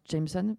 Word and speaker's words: JMSN: Ça se prononce Jameson, mais ça s'écrit J-M-S-N.